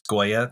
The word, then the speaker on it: Goya: Am I saying it correctly? It's Goya, the artist? – Yes, the Spanish.